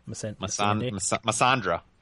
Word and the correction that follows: Missandei: Something like that. – Missandei. – Miss Sandy. – I think it's Missandei. – Okay. – That's how I pronounce it.